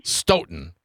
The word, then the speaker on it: Stoughton: God, I always say it wrong. How do I pronounce your fine city properly? – Stoughton. – Stoughton.